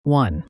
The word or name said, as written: won